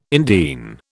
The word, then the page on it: indene: British English